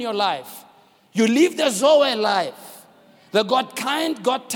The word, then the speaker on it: Zoe: Z-O-E, pronounced Zoe.